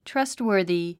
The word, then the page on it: trustworthy: trʌ́stwə̀ː r ði; トゥラストウォディ